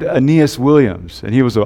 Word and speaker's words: Aeneas: And the reason I know how to pronounce that name so well, Aeneas, is because there used to be a football player on the Cardinals.